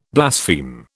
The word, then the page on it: blaspheme: British English